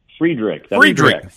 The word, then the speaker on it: Freiderich: Do you pronounce it Frederick or Friedrich? – Friedrich. – Friedrich. Okay. – Yeah, well done.